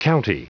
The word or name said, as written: county